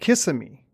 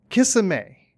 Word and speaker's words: Kissimmee: it's pronounced Kissimmee, and she said, it is not, it's pronounced Kissimmee, and they were going back and forth about the correct pronunciation, and finally they stopped to get a bite to eat.